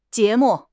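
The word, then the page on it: jie2 mu4: tone 2; tone 4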